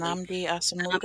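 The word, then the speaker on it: Nnamdi Asomugha: Namdi Asomugha.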